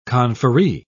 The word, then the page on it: conferree: British English